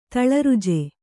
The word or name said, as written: ತಳರುಜೆ